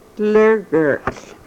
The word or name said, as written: lrgrl